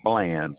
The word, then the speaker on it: Bland: Yes, my name is Wayne Bland. – You say your name is what, sir? – Wayne, W-A-Y-N-E, Bland, B-L-A-N-D.